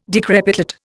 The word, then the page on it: decrepitate: Indian